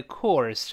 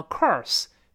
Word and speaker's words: course: 前面的 of 弱化呢，呃呃呃呃，前面几乎听不到了，特别轻啊，就前面的字母 o 变成一个倒意思的呃呃，然后上牙一竖，下着 of of of course，把力气放在后面的 course 上，不要读成 course，是 course，先发 o，再发勾舌尖的 er or course，有一点先发宽阔的阔，呃，再发儿子的儿，有一点像 core 这个声音啊，yes of course。